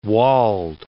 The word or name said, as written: Wald